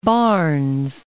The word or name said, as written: Barnes